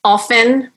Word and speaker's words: often: Often, like without the T.